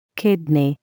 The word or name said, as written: kidney